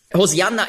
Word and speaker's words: Hosianna: Hosianna ist eben die griechische Aussprache von Hebräisch Hosianna. Das kommt daher, im Griechischen gibt es keinen Sch-Laut.